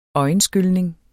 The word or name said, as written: øjenskylning